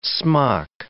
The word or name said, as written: smock